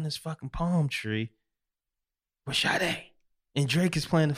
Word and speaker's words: Sade: It was like, hi, I'm Sade.